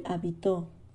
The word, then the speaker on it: hábito: No lleva acento en la última O, lleva acento en la primera A. Es una pena que el hábito se haya perdido. No el hábito, de habitar no, sino hábito.